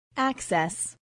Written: access

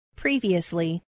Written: previously

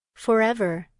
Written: forever

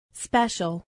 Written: special

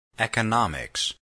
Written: economics

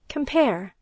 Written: compare

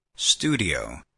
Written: studio

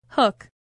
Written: hook

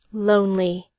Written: lonely

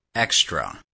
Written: spare

